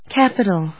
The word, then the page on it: capital: cap・i・tal; kˈæpəṭl